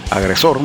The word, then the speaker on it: Agresor: Agresor, mejor dicho, sin acento en inglés.